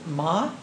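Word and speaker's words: ma (間): There's a Japanese word called, correct me if I'm mispronouncing it, ma, M-A, is that right? – Mm-hmm.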